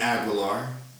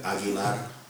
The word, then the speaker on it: Aguilar: In layman's terms, you're hearing the voice of Robert Aguilar. That's the American pronunciation. My mom would say Roer Aguilar. Colombian.